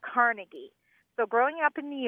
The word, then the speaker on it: Carnegie: Carnegie. – Carnegie, accent on the second syllable. – With more of an A than an E.